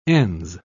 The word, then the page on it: ens: British English